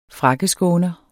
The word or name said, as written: frakkeskåner